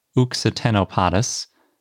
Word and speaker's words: Occitanopodus: The new ichno genus is named Uxitenopodus, although it's spelled like Occitanopodus, but I'm using the French pronunciation because it's named after the Occitanie region in France where it was found.